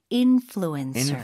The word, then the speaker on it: influencer: 그 P 발음 쓰시면 안 되고 F 발음 쓰셔야 돼요. 인플루언서. 네, 부드럽게 influencer.